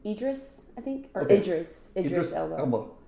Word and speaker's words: Idris Elba: Idris Elba? – I think so, yeah. – Say it again. – Idris Elba.